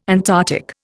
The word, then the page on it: Antarctic: Indian